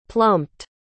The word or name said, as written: plumped